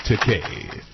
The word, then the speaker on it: Takei: First of all, it's Star Trek, and it's pronounced Takei.